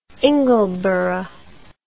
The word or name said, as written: ingleborough